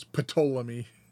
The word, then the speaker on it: Ptolemy: And as I said, it was established under the rule of Ptolemy, which is spelled P-T. So it's Ptolemy.